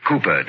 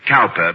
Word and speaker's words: Cowper: Cooper, darling. – Hmm? – It is spelled Cowper, but pronounced Cooper.